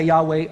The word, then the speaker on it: Yahweh: We're talking about a covenant God, and God revealed himself as Yahweh. That's the way we most of the time say it.